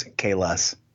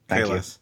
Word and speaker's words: Kallus: I'm sorry, it's Kallus. Kallus. Come on, pronounce your goddamn Klingon right, Paul.